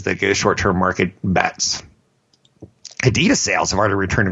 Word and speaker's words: Adidas: I learned the correct way to say it is Adidas.